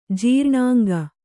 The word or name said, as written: ಜೀರ್ಣಾಂಗ